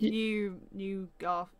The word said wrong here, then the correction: Newgarden: Newgarden. Yeah. Close.